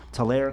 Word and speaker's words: Tulare: They're based right here in Tulare, California.